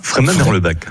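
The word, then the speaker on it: Freyming-Merlebach: J'ai bien dit Fréminges ou Fréminges ? – Fréminges, Merlebach. – Merlebach. Ah ben, j'ai bien prononcé.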